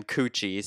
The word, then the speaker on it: Cucci: Last name, Cucci, spelled like Gucci, but with a C.